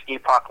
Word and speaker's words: Epoch: It's Epoch like the frame of time. I pronounce it the British way because it's a homage to how I said as a kid because it's from Chrono Trigger.